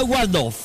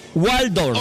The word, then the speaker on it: Waldorf: Ensalada Waldorf. – ¿Cómo? – Waldorf.